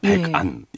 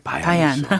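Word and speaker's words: 마얀이죠 (伯颜): 백안 예. 이 예. 말에 이제 중국식 발음이 마얀이죠.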